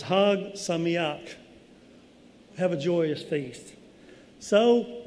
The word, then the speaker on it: Chag Sameach: And it doesn't quite pronounce, this is translated, but it doesn't quite pronounce how it looks. It's Chag Sameach.